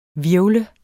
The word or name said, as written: hvirvle